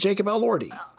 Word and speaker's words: Jacob Elordi: How is it spelled? – Elordi. – Yes. – And that's how you say it, Jacob Elordi.